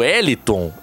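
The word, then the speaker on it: Heliton: Sobre o Eliton, ele tem 23 anos, não é o Wellington, não tô é. falando errado. É, é H-E... Com acento, é, acento, com acento L-I-T-O-N.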